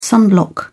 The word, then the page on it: sunblock: British English